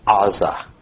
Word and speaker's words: Gaza: Shimshon went to Gaza, which, by the way, is usually pronounced Aza with the G silent, where he saw a prostitute and went in to spend the night with her.